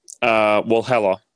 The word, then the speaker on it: Walhalla: Walhalla.